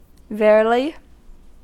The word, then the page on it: verily: American English